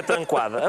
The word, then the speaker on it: Tranquada: o senhor Tranquada Gomes. Eu fui pesquisar. – Eh, tranquada ou tranca? Como é que se pronuncia? Tranquada, é tranquada? diz Tranquada Gomes.